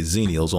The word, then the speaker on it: Xennials: So, and I can just say Xennials only because it's an X in the front. And that's usually pronounced with a Z.